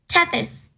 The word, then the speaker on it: Tethys: And it's called Tethys? Is that how you pronounce it? – Tethys.